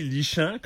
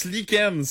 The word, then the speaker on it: lichen: Quelque chose qui se lit lichant, comme disait Marion. – Ben ouais, tu... – Pourquoi on le prononce lichen? C'est quoi le rapport? – Ça devrait être écrit comme L-I-K-E-N-N-E.